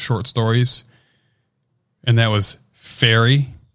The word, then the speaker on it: Faerie: It's Fairy, F-A-E-R-I-E.